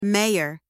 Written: mayor

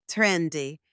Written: trendy

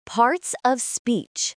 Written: parts of speech

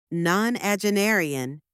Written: nonagenarian

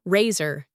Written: razor

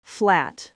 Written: flat

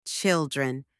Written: children

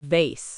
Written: vase

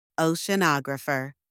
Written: oceanographer

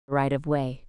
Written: right-of-way

